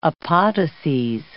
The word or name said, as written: apodoses